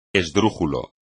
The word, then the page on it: esdrújulo: espagnol